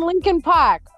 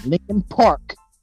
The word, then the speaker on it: Linkin Park: They have Paramore and Linkin Park, okay? – Hey. Stop real quick. – Yes? – Linkin Park has an R in it. Pronounce the damn R, you fucking northerner.